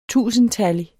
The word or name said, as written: tusindtallig